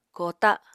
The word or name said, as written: koota